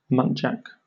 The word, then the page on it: muntjac: British English